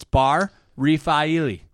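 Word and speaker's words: Bar Refaeli: But and no, I went online and found out how to pronounce it. It's Bar Rafaeli.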